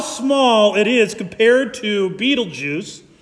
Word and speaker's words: Betelgeuse: There are even there's a star known as, sometimes it's pronounced Betelgeuse, but I prefer the pronunciation Betelgeuse because I grew up in the 80s and I love that movie.